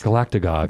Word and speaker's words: Galactagogues: If I want to learn more about you guys, I find you on Facebook, but also Galactago. You can pronounce it, Galactagogs. – That's it. – Please spell this for me. .com. – GALACTAGOGUES. – Galactagogs.